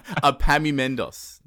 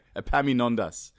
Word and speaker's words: Epaminondas: I'm going to butcher this name, Jesus Louise. Epaminondas. There we go. Epaminondas.